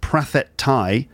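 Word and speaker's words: Prathet Thai (ประเทศไทย): Second thing, Thailand, the country name, or I think in Thai that's Prathet Thai. That's how they say it.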